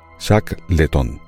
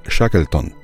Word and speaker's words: Shackleton: Bueno, escucharéis a lo largo del episodio que viene ahora cómo yo pronuncio el apellido Shackleton como Shackleton. Y esto es así porque cuando estuve grabando ese episodio yo grababa ese apellido tal y como se pronuncia en español, Shackleton. Sin embargo, en inglés no se pronuncia así, sino que se dice Shackleton.